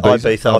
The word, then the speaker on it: Ibiza: And he reckons, well, Dusty reckons I should go to Ibiza. Is that how you say it? – Yeah, Ibiza.